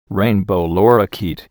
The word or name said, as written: rainbow lorikeet